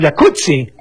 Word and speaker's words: jacuzzi: Entonces deberíamos pronunciar el jacuzzi. – Exacto. – Como pizza. Como Rampazzo, sí. Como Claudio Rampazzo.